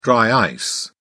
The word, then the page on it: dry ice: British English